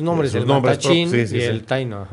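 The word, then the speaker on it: Taíno: ¿Taino? – Sí, sí.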